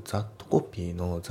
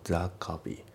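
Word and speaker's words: that copy: A の A newsletter、で、発音ですけど、まずザットコピーのザットの T が抜けますね。ザカピー、ザットコピーじゃなくてザカピーだね。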